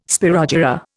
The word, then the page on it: spirogyra: Indian